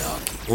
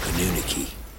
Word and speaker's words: Anunnaki: That she's part of the Anunnaki? – It's actually pronounced Anunnaki. Only that mushmouth pronounces it Anunnaki.